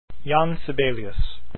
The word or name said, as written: sibelius